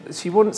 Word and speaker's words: Siobhan: Em, eh, Shibon se escribe Siobhan este, eh, pero eh, yo hice una entrevista con Shibón que te la recomiendo.